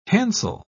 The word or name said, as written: handsel